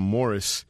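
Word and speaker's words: Maurice: Los hermanos Gibb, Barry, Robin y Morris, pero ellos lo pronunciaban Morris, ahí en Lonely Days.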